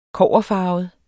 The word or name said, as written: kobberfarvet